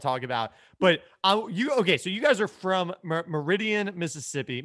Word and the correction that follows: Meridian: Am I saying this correctly? – No, Meridian.